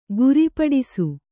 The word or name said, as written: ಗುರಿಪಡಿಸು